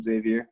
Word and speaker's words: Xavier: I think you pronounced that Xavier, right? – Yeah.